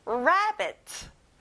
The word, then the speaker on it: rabbit: 토끼, rabbit. 앞에 re가 아니라 ra예요. Rabbit.